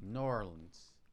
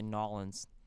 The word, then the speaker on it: New Orleans: New Orleans. – No, dude. You got to say it like them. You just got to fucking say Nolans.